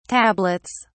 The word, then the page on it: tablets: British English